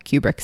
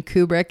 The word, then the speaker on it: Kubrick: And I realize I've kind of been going back and forth on pronunciation between Kubrick and Kubrick, depending on what point in the sentence I am. I think that his family preferred that it's Kubrick.